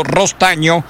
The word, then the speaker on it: Rostagno: Gonzalo Rostagno se escribe, se pronuncia Gonzalo Rostaño.